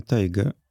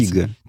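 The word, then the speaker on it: Tiggo: Не Тайга. – Ну, написано Тайга. Тиго? Или как это читается? – Тига. – Тига.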